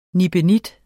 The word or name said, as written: nibenit